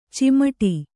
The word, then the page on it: ಚಿಮುಟು: cimuṭu